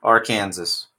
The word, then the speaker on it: Arkansas: Arkansas. That's how it's pronounced, sir.